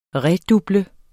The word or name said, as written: REDUBLET